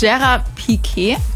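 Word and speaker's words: Gerard Piqué: Damals hat sie nämlich ihren Fußballstar eigenen kennengelernt, Gerard Piquet.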